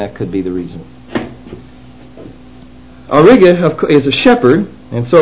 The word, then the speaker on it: Auriga: The other one is Auriga, Aurica, it shows it here. Let me see if I'm pronouncing that right. No, I have it Auriga here. I'll just check it for you in a hurry just to make sure we get it right. It's got a G, so this chart has it wrong.